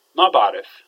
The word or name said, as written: maaba3rif